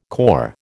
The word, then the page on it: cor: American English